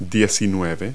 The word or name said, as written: nineteen